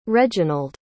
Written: reginald

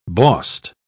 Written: bossed

